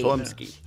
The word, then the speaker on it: Chlumsky: Chlumsky. Chum, something like that. But it's a C-H. Chum. – Yeah. C-H-L-U-M-S-K-Y. Chlumsky. – Chlumsky.